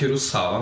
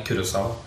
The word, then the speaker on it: Kurosawa: Egentlig eh, uttales det Naruse? – Naruse. – Okay. Akkurat som Det heter ikke Kurosawa, men Kurosawa.